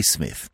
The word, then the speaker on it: Psmith: So I decided to adopt the Smith. The P, I should add for your guidance, is silent, as in Thysis, Psychic, and Ptarmigan.